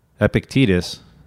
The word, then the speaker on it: Epictetus: Epictetus, which is spelled E-P-I-C-T-E-T-U-S, but it's pronounced Epictetus, was born a slave in AD 55 in the eastern end of the Roman Empire.